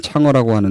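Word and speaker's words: 창어라고 (嫦娥): 항아를 중국식으로 발음하면 창어라고 하는데 이 신화에서 창어는 신묘한 약을 먹고 지상에서 달로 날아갔습니다.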